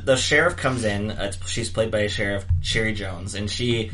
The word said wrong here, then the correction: Cherry Jones: Is it Sherry or Cherry? – Sorry, it is Cherry Jones. Okay. I think so. – It's spelled Cherry.